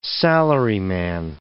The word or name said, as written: salaryman